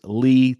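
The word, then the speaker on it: Lea: Follow me at Chris Lee TV. My last name is spelled L-E-A. I don't know what's up with this L-E-E business.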